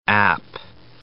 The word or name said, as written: app